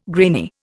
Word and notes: grainy: Indian

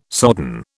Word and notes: sodden: British English

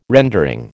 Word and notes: rendering: American English

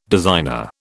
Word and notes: designer: British English

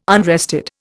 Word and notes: unrested: Indian

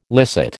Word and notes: licit: American English

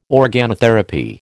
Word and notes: organotherapy: American English

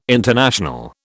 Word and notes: international: British English

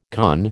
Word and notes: conn: American English

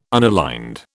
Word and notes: unaligned: British English

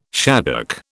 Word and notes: shaddock: British English

